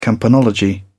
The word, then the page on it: campanology: British English